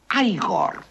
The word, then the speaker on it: Igor: No, se pronuncia Igor.